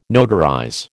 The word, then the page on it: notarize: American English